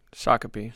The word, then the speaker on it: Shakopee: Shakope? Sh- Shakopee. Shakopee. – Oh, Shakopee. That's how you say it.